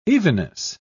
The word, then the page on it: evenness: British English